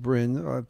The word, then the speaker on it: Brun: It's B-R-U-N. They say it's pronounced Brin, but let's say the Brin is proper.